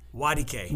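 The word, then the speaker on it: Nwadike: The N is silent. – Wadike. – Wadike, yeah. So if you pronounce it phonetically, it would sound like wa, like W-A, and then just the letter D, and then K-A-Y is how it would sound. That's Wadike. Easy.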